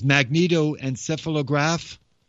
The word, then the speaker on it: magnetoencephalograph: But with the new technology called MEG, which is magnetoencephalograph, did I say that right? Encephalograph? Yes, I did.